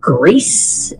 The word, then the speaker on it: Gris: The most recent one was I played a game called, I think it's called Grease, is how you pronounce it correctly. But it's G-R-I-S. I said Gris until I saw it on a YouTube channel.